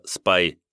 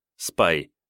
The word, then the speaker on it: SPY: На упомянутом мной рисунке видно, как стоимость SPDR S&P 500 ETF Trust SPY, обычно произносится как слово SPY, английское шпион, SPY, да-да, как секретный агент, в марте 2020 года обрушилась с $340 за акцию до примерно $220 за считанные недели.